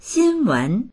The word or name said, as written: xin1wen2